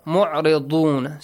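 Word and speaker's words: مُعْرِضُونَ: Wahum mu'ridun. Ayn again, then dad. Dad shouldn't be pronounced like dhal or dha.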